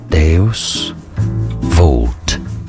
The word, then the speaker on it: Deus Vult: How do you pronounce this Latin phrase? In English, it's pronounced as Deus Vult, a Christian motto relating to divine providence, meaning God wills it.